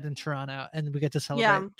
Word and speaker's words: Toronto: You don't really have the second T. It's Toronto.